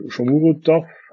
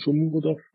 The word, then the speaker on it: Shomurodov: Und stattdessen hatten wir zum Beispiel Shomurodov, habe ich richtig ausgesprochen, Shomurodov geholt von Genua. – Ich denke. – So reinigermaßen.